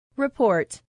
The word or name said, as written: report